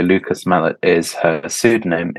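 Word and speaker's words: Lucas Malet: Before we move on to Dickens, can you tell us a little bit about Lucas Mallet? Am I pronouncing the Mallet? – Lucas Mallet.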